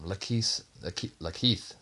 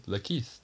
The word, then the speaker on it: LaKeith: Deathstorm, Lakeith Stanfield, I should say. I mispronounced that by accident. Lakeith.